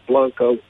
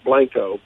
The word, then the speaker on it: Blanco: I will pronounce it Blanco. We Texans call it Blanco.